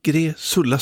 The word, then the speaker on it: Gräsulla: Eller Gräsulla, det var svårt att uttala det där. Gräsulla, så ska det vara.